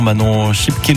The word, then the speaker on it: Schipke: Puis sinon, Manon Schipke nous a envoyé un message. Oui, ça se prononce comme ça, son nom de famille. C'est OK.